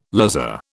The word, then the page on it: lazar: British English